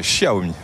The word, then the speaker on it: Xiaomi: Avec la prononciation de Xiaomi aussi. Tout à fait, Xiaomi.